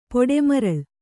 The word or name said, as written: ಪೊಡೆಮರಳ್